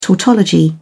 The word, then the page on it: tautology: British English